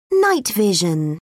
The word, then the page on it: night vision: British English